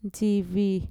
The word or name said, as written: ntxivi